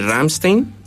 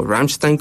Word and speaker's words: Rammstein: Y pues no sé, en la tercera banda estoy debatiéndome entre Rammstein, o Rammstein como uh-huh. lo pronuncian ellos, y Foo Fighters, yo creo que una de las dos.